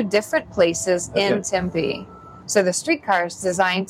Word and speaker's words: Tempe: I notice you and everybody here says Tempe. Out east where I'm from, we all say Tempe, but you're supposed to put the pronunciation.